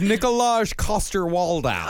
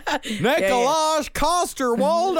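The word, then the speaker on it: Nikolaj Coster-Waldau: His name is Nicolaj Coster-Waldau. – Oh, yeah, I'm sure you pronounced it properly. Yeah. – Nicolaj Coster-Waldau.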